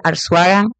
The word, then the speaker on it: Arzuaga: Instagram, Facebook, Lizel Arzuaga,